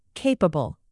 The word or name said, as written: capable